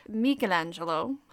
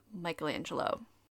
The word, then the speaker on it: Michelangelo: Before anyone tries to get sassy with me, I am perfectly aware that Michelangelo's name is actually pronounced Michelangelo, but we here at Stuff About Things like to keep the pretension to a minimum, so we will continue with the tried and true pronunciation of Michelangelo.